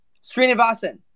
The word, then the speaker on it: Srinivasan: Srinivasan, there we go.